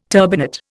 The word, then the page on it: turbinate: Indian